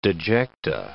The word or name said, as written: dejecta